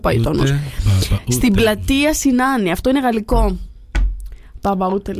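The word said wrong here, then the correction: Papaoutai: λέει. – Ωραία, ποιος τραγουδάει. – Και το λένε Παπαούτε.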